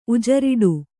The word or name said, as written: ಉಜರಿಡು